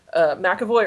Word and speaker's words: McAvoy: Deadpool says, how do you say it, McAvery? – Oh, yeah, no. So, yeah, so, yeah. He makes reference to Xavier, and he's like, so wait, McAvoy or Stewart? – Yeah, there you go.